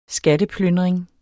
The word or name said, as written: skatteplyndring